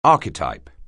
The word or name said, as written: archetype